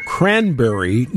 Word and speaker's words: Cranbury: Today we go to Cranberry, New Jersey. – Cranberry. – And it's spelled C-R-A-N-B-U-R-Y.